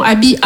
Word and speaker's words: Aby: » Non, comme je vous ai dit, Abby, c'est mon prénom. Abby, A, B, Y, trois lettres, deux syllabes.